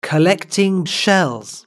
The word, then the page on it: collecting shells: British English